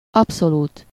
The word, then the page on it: pur: [pyʁ]; France